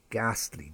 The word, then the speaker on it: ghastly: Ghost, G-H-O-S-T, but we don't pronounce that ghost, it's ghost, G-H-O-S-T, ghost. Ghastly, that was a ghastly accident last week, ghastly, G-H-A-S-T-L-Y, not G-A-S, ghastly. And I like to eat gherkins on my burgers, who doesn't? Gherkins, G-H-E-R-K-I-N-S, gherkins, not G-E-R.